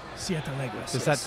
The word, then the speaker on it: Siete Leguas: But I love the Herradura for every day, and I love the Siete Leguas. – Siete Leguas. – Did I say that right? – Good. Close enough.